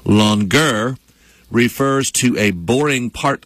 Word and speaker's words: longueur: L-O-N-G-U-E-U-R. Longer. – Well, all right. – That's how it's pronounced.